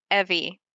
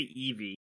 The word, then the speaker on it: Evi: The audiobook has it Evie. – Oh, I'm still wrong. – What did you say? Evie? – I say Evie. – Evie like the Pokemon?